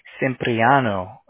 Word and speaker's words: Cipriano: C-I-P. – Mm-hmm. – R-I. – Mm-hmm. – A-N-O. – Cipriano.